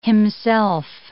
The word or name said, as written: himself